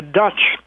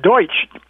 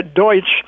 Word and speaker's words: Deutsch: I mean, when I'm calling that, or the non-Germans in Pennsylvania, referred to them as Pennsylvania Dutch, which was really a mispronunciation of the word Deutsch. They should have said Pennsylvania Deutsch, as you and I know, that means German.